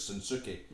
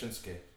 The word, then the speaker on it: Shinsuke: Like, we pronounce it in our bastardised version of English as Shinsuke. – Yeah. – But his name is actually pronounced Shinsuke.